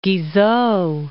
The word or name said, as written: Guizot